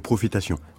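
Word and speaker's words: Pwofitasyon: Mais c'est profitation. – On prononce le R comme en français, ça s'écrit avec un W, ça s'écrit en créole en fait.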